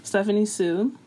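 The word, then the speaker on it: Stephanie Soo: Also, so he recently got into some beef with fucking Stephanie So, or is that how you pronounce her name? Stephanie Sue.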